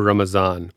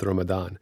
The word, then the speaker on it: Ramadan: For example, Ramadan or Ramazan, both are common pronunciations, and we chose to go with Ramadan.